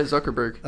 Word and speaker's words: Zuckerberg: Is that me saying that right? Now it's like his name? – We've been talking about it for so long that it like sounds weird now. – Yeah, that's right, Zuckerberg.